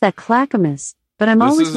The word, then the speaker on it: Clackamas: Alexa, how do you say Clackamas? – I pronounce that Clackamas, but I'm always working on how I say things, and I might not have it right.